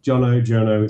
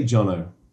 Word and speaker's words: Jono: Jono, it's fine. – What do you prefer? And I'll call you what I swear to God. – Let's say Jono.